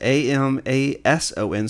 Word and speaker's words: Amason: A M A S O N, so uh, Amazon with an S um, and I called it.